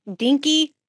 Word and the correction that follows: Denk: Did I say it right? No? – That's great. It's Dank. D-E-N-K, okay, Dank Studios.